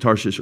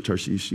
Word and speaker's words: Tarshish: However, you want to pronounce it Tarshish or Tarshish, you can pronounce it either way.